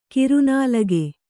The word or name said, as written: ಕೂರ್ನಾಲಗೆ